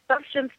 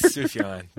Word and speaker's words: Sufjan: But yeah, no, the Sufjan Stevens, I'm not even pronouncing it right. How do you pronounce it? – I think it's Sufjan, but it's spelled really Sufjan, that's it.